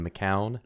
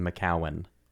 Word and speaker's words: McCown: Well, but I grew up in Oklahoma, and so even though phonetically it would be McCown, everyone, including my parents, says McCowan.